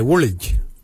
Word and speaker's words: wolidj: For calf muscle, we say woolij. Woolij. Woolij is spelled W-O-L-I-D-J.